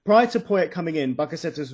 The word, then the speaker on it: Bakasetas: Yeah, I think your captain, I'm not sure how you pronounce his name. Can you say it again? – Bakasetas. – Bakasetas.